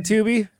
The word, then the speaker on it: Tubi: You know what I like about Tubi? Rhymes with boobies.